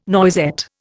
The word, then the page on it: noisette: Indian